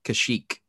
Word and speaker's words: Kashyyyk: Kashyyyk.